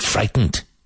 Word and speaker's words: frightened: Don't get frightened. Don't get frightened. Now, this word, you can actually say this word without pronouncing, well, the T must be pronounced, but very, very lightly.